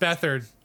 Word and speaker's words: Beathard: Not Bethard. It's pronounced Bethard.